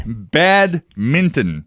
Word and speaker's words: badminton: First of all, did you know that it's actually spelled and pronounced badminton? – I did not know that. – Yeah, I didn't either. – No. – I have this app that reads news to me. I put the articles into it, it reads it back to me, and I never even realized it was spelled that way. – Neither did I. – Badminton. – Badminton. – Yes. Every time you say that from now on, make sure you include the end.